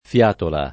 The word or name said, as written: fiatola